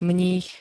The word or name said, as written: mních